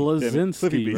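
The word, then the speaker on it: Bleszinski: Cliff Blinsky. How the fuck do you say his name? – Blinsky? Whatever it is, it's Cliffy B. – Blazinski, right? – Yeah, yeah.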